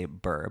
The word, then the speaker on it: Bure: It's B U R E, but there's no accent on the E, unlike Simone, which is pronounced Simone.